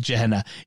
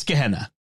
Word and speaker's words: Gehenna: The deacon kept calling it Gehenna. It's Gehenna. It's a hard G, but it's okay.